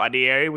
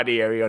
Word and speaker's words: Adeyeri: Instagram, Damilare underscore Adieri, which is my name.